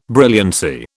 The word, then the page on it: brilliancy: British English